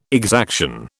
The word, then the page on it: exaction: British English